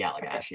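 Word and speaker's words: Allagash: I think I'm saying that right, Allegash? – Yes, it's the Allegash, okay.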